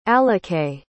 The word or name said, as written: alikae